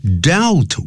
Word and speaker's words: dealt: His comic strip，他的这个连环漫画，dealt with deals 啊，D E A L，如果是现在时第三人称单数还加个 s，那么过去时就要念成 dealt。